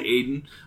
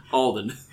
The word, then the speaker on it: Alden: Sorry, what did I say? Aiden? Alden.